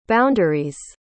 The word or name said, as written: boundary's